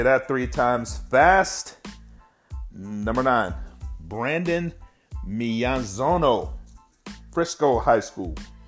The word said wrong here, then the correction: Miyazono: Let me say that again. Miyazono.